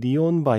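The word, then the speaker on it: Neon: 이 영화에 아케드 파이어 2집 네온 바이블 네. 원래대로 하면 니온 바이블이 아마 맞는 발음 아닌가요?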